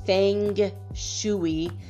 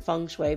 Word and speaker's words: Feng Shui: It's F-E-N-G Feng dash Shui, S-H-U-I. It's spelled like in English if you want to pronounce it wrong. It'd be Feng Shui. That's not, I mean, it's pronounced different, Feng Shui, but F-E-N-G dash, that little dash, and then S-H-U-I dot love to know, K-N-O-W, .com/, and then it's Feng Shui Tips, Feng Shui Health Cures.